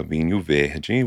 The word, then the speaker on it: Vinho Verde: This week's wine is Miguel's, and it is a Vinho Verde, which is a Portuguese wine, literally. – I was going to say, you just pronounced that very Portuguesely.